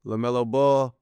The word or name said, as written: LaMelo Ball